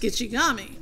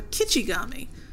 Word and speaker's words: Gitche-Gumee: The Ojibwe named for the lake is Gitche-Gumee, pronounced Gitche-Gumee or Kitche-Gumee, meaning Great Sea.